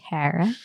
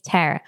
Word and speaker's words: Tara: Tara. It's Tara.